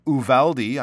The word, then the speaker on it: Uvalde: Uvalde, I'm pretty sure is the way uh, we pronounce that that uh, town name in southern Texas.